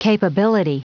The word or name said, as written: capability